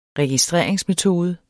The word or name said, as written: registreringsmetode